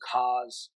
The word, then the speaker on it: Hasz: In the Texas A&M game three or four weeks back, boy, they had a tight end, a true freshman, Luke Hasz, I believe is how you pronounce his last name, H A S Z.